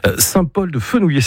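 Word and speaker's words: Saint-Paul-de-Fenouillet: Perpignan, vous irez un petit peu plus tard parce que c'est un peu la grande ville pour vous, mais Saint-Paul-de-Fenouillé, c'est comme ça que vous le prononcez.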